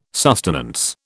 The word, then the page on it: sustenance: British English